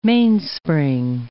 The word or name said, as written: mainspring